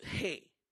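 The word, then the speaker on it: hey (ה): It's the hey. See, that's how you pronounce the fifth letter of the Hebrew alphabet. Hey. That's how you pronounce it.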